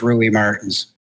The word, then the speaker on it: Rui Martins: Am I saying that right, Rui? Please let me know. What's the French version of that? – No French, Portuguese, actually. It is Rui Martins.